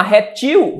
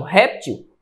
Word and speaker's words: réptil: Se eu chamar reptil, vai ser oxítona. Xerox, oxítona. Mas se eu antecedo a sílaba tônica e chamo réptil ou xerox, eu tenho o quê? Paroxítonas. Beleza?